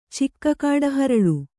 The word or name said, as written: ಚಿಕ್ಕಕಾಡಹರಳು